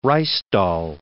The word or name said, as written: Ruysdael